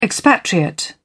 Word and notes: expatriate: British English

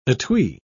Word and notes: etui: British English

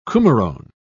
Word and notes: coumarone: British English

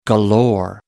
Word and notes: galore: American English

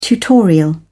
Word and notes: tutorial: British English

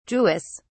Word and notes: jewess: British English

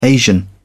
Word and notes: asian: British English

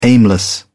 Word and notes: aimless: British English

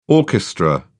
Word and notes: orchestra: British English

